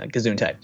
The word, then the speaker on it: Gesundheit: God, God bless me, uh, Gesundheit.